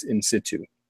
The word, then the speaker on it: in situ: So it's I-N hyphen S-I-T-U. In situ, and it means in the wild. When somebody does work in the wild in a natural habitat with an animal, that's in situ.